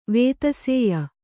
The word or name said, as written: ವೇತಸೇಯ